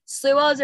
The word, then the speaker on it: sluagh: Okay, so my next one is a Scottish fae, and it's called a slua. It's not spelled like it sounds, but it kind of is. It is S-L-U-A-G-H. – That's slua.